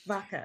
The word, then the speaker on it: Vaca: So the Spanish pronunciation is Vaca. – Vaca.